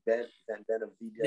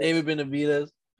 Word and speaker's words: Benavidez: Like, so how you say his name again? Benavidez. – David Benavidez.